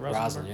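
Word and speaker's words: Rosalynn: Is that how you say it? Rosalind, yeah.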